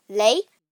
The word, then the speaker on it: les: The third type is les, which is pronounced lay, which um, is used when the next word is plural.